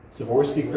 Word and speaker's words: Swarovski: And then, but that's not the kicker, on top of the tree, there is a, how do you say it, Swarovski? Swarovski crystals? – Yes.